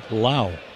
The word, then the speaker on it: Lowe: Spells his name L-O-W-E, pronounces it Lau.